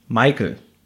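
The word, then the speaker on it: Meikel: Ich habe immer wirklich gedacht, das liest sich, wenn die den Namen aussprechen, wie Michael, dass sie ihn alle Michael nennen. Er wird wirklich Michael geschrieben, und zwar Martha, Emil, Ida, Kaufmann, Emil, Ludwig. So schreibt sich der Michael.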